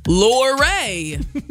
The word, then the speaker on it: Luray: And I was like, well, how do you say lore? Luray. Not love. You don't say love. You don't say lure. – No.